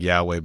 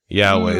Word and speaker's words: Yahweh: It, it, it, you would sound it out like Yahweh, but we don't say that. You say Adonai, which is like Lord Anyway, do we think?